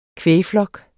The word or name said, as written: kvægflok